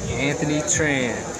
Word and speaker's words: Anthony Tran: Anthony Tran. – Anthony Tran, how do you spell that? – A-N-T-H-O-N-Y, Tran, T-R-A-N.